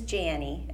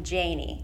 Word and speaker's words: Jannie: So my name is Janie. It's spelled like Annie with a J. But a lot of people like to call me Janie, which is not right.